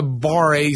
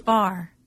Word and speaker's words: barre: What is a bar A studio? Or am I saying it wrong? – That's bar. So that's like ballet bar. So it's like an exercise place. – B-A-R-R-E? – Yeah, that's bar.